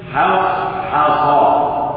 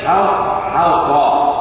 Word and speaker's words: حصحص: فهو يحذرك من ان تفخم الحاءين الحاءين لمجاورتهما للصادين المفخمتين المطبقتين فتنطق بالحاء وتخطئها هكذا حصحص. هذا خطا حصحص. ما الذي جعلك تقول حاء بدلا من حاء؟